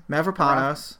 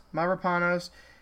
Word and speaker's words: Mavropanos: Mavropanos. – Mavropanos.